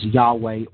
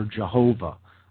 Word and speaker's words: Yahweh: And this, if we transliterate into the English language, is transliterated as YHVH, which comes down to us in the English pronunciation as Yahweh or Jehovah, again, names for God in the Judaic tradition.